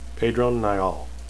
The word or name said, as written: pedron